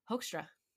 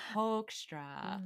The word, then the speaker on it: Hoekstra: I think it's Hoekstra. – There we go. Thank you. – You're welcome. We help each other. We sound it out. Hoekstra.